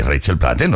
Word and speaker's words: Rachel Platten: Ah, sí, Rachel Platten, lo dije bien.